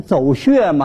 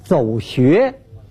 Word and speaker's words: 走穴: 演艺圈里头啊有一种专业用语，像什么走穴呀，哎，那个说不念走穴吗？这读音读错了，啊，应当是走穴。